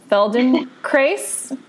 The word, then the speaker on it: Feldenkrais: She's a certified infant developmental movement educator, an aware parenting instructor, a body-mind-centering practitioner, a trained, I'm going to butcher this, Feldenkrais? – Yep. Okay. Don't put it on the end.